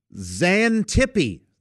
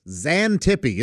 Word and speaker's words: Xanthippe: And this week's word is Xantippe. Xantippe. Isn't that fun to say? And it's spelled... with an X, like xylophone, so Xanthippe, Xantippe.